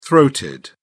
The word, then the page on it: throated: British English